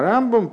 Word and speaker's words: Рамбам: Рамбана а, для того, чтобы не путать э, Рамбана и Рамбама, э, мудрецы специально решили, что Рамбана будут говорить с ударением на второй слог, а Рамбама на первый.